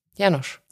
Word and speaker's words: Janusz: Janosch. – Janusz. Janusz Korczak. Ach so, Janusz oder Janosch? Janusz. Janusz, Entschuldigung. – Glaube ich, oh Gott, ich hoffe es jetzt.